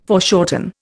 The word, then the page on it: foreshorten: Indian